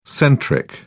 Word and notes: centric: British English

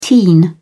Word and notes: teen: British English

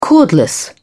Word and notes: cordless: British English

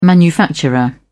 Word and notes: manufacturer: British English

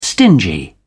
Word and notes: stingy: British English

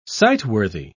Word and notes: sightworthy: British English

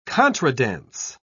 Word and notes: contradance: British English

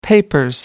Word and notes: papers: British English

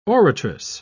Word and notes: oratress: British English

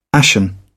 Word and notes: ashen: British English

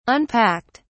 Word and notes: unpacked: British English

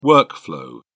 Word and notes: workflow: British English

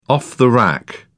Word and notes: off-the-rack: British English

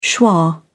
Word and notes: schwa: British English

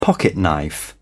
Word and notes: pocketknife: British English

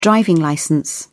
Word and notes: driving licence: British English

